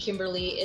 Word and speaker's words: Kymberli: And Kimberly is spelled a little bit differently.